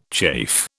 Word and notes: chafe: British English

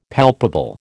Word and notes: palpable: American English

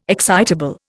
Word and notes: excitable: Indian

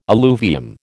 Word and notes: alluvium: American English

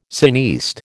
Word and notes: cineaste: American English

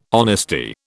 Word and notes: honesty: British English

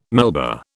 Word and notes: Melba: British English

